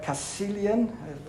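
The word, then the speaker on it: Caecilian: This became a headline point in about 311 when a bishop, Cassilian, that's his right pronunciation,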